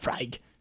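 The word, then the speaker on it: Laphroaig: Spelled L-A-F-R-O-Y-G. – Lafroig. – Freig. – Yep. Lafroig. – Malted Scotch whiskey from the remote island of Islay in the Western Isles of Scotland. – Islay. – Lafroig is a Gaelic word meaning the beauty hollow by the Board Bay.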